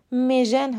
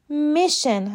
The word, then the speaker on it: mission: اولین نکته در مورد تلفظ لغت میژن هست. تلفظ درست این کلمه میشن هست و میژن غلطه.